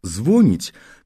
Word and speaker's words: звонить: А вот слово «звонить», которое то и дело произносит с ударением на первом слоге, неправильно по звучанию, по самому произношению своему.